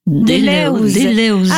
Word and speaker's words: Deleuze: Alors, comment il faut le prononcer? Deleuze? – Deleuze. – Ah, Deleuze.